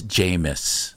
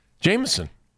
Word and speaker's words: Jameis: The quarterback's name is Jameis. – Oh. – It's not an extra syllable. It's Jameis.